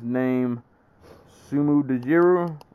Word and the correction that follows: Dajiri: or Dajiri. Sorry, that was an I at the end, not a U. Sumu Dajiri, and they spelled his name Sumu Dajiri, Sumu Dajiri.